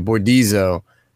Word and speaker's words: Bordizzo: And now she's live action in Natasha Liu Bordoza. – That's not how you say it. Thank you. Thank you. Bordizzo crushes it.